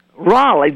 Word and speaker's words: Rawleigh: Raleigh, W.T. Raleigh Company. And that's spelled R-A-W-L-E-I-G-H.